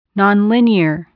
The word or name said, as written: nonlinear